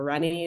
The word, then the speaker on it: Renee: And yeah, at one point I had a organizational enthusiast and creative was in there, it was in the bio, but yeah, my name is Renee, and or Renee, it doesn't matter how you Trinidadians pronounce it, however they want, and anybody else pronounces it Renee usually.